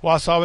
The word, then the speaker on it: Wausau: I know I said it right, Gary. Wausau, Wisconsin.